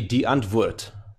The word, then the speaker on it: Die Antwoord: It's actually an Afrikaans word, so it's actually die Antwoord. So it's more, you must try and say it with a German.